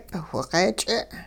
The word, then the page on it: whiq'eech'e': hupa